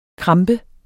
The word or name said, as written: krampe